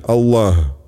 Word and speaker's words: Аллах: Например, если человек произносит «нет божества, кроме Аллаха», то имя Создателя Аллах он должен произнести правильно. Здесь особое внимание уделяется последней букве «г», напоминающей по произношению украинскую «г». А